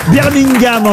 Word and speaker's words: Birmingham: Birmingham, en tout cas. On ne doit pas prononcer le G, hein, il paraît.